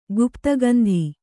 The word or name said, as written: ಗುಪ್ತಗಂಧಿ